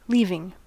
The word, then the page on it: leaving: American English